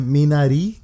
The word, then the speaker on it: Minari: Eh, le sigue en nominaciones Minari, que es una película, así se pronuncia, es una palabra en coreano.